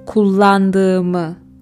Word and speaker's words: kullandığımı: The way you would say it in Turkish is kullandığımı. Bugün oy kullandığımı söyledim. We have the soft G, the G with the hat, h sound, to say it.